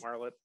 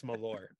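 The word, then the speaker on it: Malört: Marlott. – Don't. We're not talking about that on this show. We're not. It's Malore. It's pronounced Malore.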